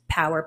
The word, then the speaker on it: PWR: It's pronounced Power, PWR, Performance Wellness Recovery.